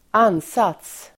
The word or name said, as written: ansats